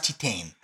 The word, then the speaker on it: Titane: And I think it's pronounced Titane. – Yeah, it's French.